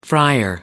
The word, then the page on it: friar: American English